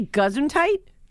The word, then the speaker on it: Gesundheit: Gesundheit, is that how you say it? – No, you say it right.